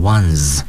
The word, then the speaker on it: ones: 由于它用的是复数形式 buses，所以 the one 在这里也要加 s，但要注意 one 加 s 以后读成 ones 啊。由于它是那个浊辅音结尾，所以发音的时候呢要读成浊辅音 z。